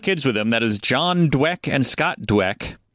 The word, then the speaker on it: Jahn: When Pollard goes in, we can call him John, but it's John spelled like Jan, like J-A-H-N.